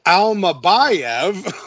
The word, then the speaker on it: Almabayev: The first round, I don't know, a couple overhands from Amalayev. I'm saying his name wrong. Almabayev Osborne.